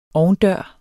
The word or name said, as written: ovndør